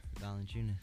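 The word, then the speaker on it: Valančiūnas: Valanciunas. – Valanciunas.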